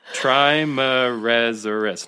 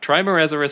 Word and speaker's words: Trimeresurus: That full name again, Trimer- Trimeresurus Salazar. Wait, I think I'm adding a syllable. Trimeresurus. No, I'm not. Trimeresurus Salazar is the full scientific name okay.